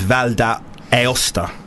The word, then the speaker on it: Val d'Aosta: The 20-something tourist, known only as Pavel, had indulged in some après-ski at a resort in Italy's Val d'Aosta. Yeah, have I pronounced that right? – I think that sounds right, yeah.